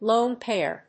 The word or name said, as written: lone pair